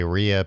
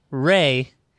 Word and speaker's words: Rhea: Ray Seahorn. I was going to say Rhea Perlman. – No, no. It's pronounced Ray. Even because it's funny, like, she played Kim Wexler, and it's like Bob and Ray.